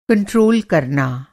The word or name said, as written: कंट्रोल